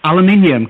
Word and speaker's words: aluminium: In a Yorkshire accent, and I obviously stumbled over aluminium because you guys, you know, pronounced it wrong. – Aluminium. – no, it's got an I in it, people.